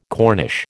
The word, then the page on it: Cornish: American English